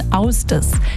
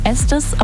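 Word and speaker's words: Ásdís: Geschrieben wird die ja Astis. Man könnte ja sagen Estis, aber so heißt sie nicht. Die Gute kommt nämlich aus Island und deswegen spricht man ihren Namen dann so aus. Austis.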